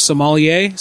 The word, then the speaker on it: sommelier: I don't even know how to spell it. Sommelier. Sommelier, I think, right? Sommelier. So I said it right.